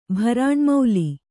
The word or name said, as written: ಭರಾಣ್ಮೌಲಿ